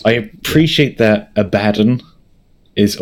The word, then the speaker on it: Abaddon: Okay, that's our lowdown on how to pronounce Abaddon. – Yes, Abaddon.